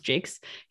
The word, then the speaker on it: Jacques: It's spelled Jacques, but I believe it's pronounced Jakes.